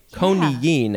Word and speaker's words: coniine: Conine. – Yeah. – And it is it's spelled C-O-N-I-I-N-E. So there's that double I, which makes you say conine.